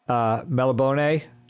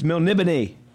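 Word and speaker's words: Melniboné: thing that should have tipped me off was he mispronounced Melibone. – Everybody mispronounces that. – Yeah, but if you're running an Elric game, it's Melnibone.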